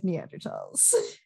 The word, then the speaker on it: Neanderthal: Like, Neanderthal is generally the preferred pronunciation these days because they were discovered in the Taal Valley.